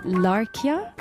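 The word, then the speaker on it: Larrakia: خلينا نروح على داروين وأراضي شعب، وداروين هي الأراضي لشعب لاراكاي، تنطق لاركيا. – لاركيا صحيح. لاركيا.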